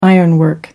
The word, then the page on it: ironwork: American English